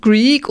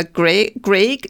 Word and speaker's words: Greig: Ja, dann ähm, 1804 lernt sie also ihren ersten Ehemann kennen, einen Leutnant äh, Samuel Greig oder Greig. Ich habe beide Aussprachen, also da wird Greig geschrieben.